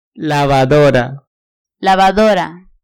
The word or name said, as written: lavadora